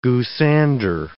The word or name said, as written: goosander